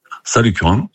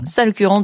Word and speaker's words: Salles-Curan: Vous êtes pompiste à, alors je ne sais pas si je vais bien le prononcer, on dit Salles-Curan ou Salles-Curan? – Salles-Curan, dans l'Aveyron. – Salles-Curan, dans l'Aveyron.